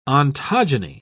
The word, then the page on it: ontogeny: British English